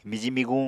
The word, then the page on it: miji-miguñ: Apatani